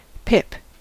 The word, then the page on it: pip: American English